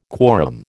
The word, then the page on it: quorum: American English